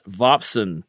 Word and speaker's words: Vopson: The mass energy information equivalence principle was proposed by physicist Melvin Vopspon. I mispronounced that. Vopson.